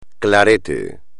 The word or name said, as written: clarete